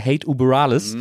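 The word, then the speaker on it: Hate Über Alles: Das gut ausgesprochen. – Ja, so muss man das doch aussprechen, weil es ist ja ein Gruß quasi zu der kalifornischen Punk-Legende überhaupt. – Genau.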